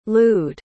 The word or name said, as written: lude